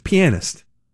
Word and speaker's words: pianist: He's like, yes, it's pianist. And he went over to the dictionary, opened it up, and you can put the emphasis on whatever syllable you want, evidently. You can see either one is correct.